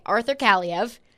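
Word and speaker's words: Arthur Kaliyev: one is a 21-year-old Arthur Kaliev. Am I pronouncing that right? – Yes. – Okay, Arthur Kaliev.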